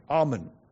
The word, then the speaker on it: almond: Almond. I beg your pardon. Note the L is silent, right? Almond. Almond. Okay.